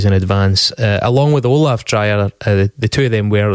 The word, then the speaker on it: Dreijer: Dreijer, Swedish. Dreyer? Dreyer? – Probably closer to Dreyer. Yeah. – Right, Dreyer. We'll go with Dreyer.